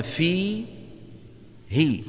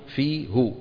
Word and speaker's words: فيه: فيقولون في هي ولا يقولون في هو، ويقولون به ولا يقولون به، تبهنا.